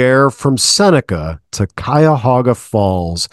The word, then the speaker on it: Cuyahoga: That whole middle part, we just leave off. And we all say Cuyahoga, Cuyahoga Falls, Cuyahoga River.